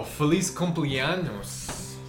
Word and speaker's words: feliz cumpleaños: So, feliz cumpleaños.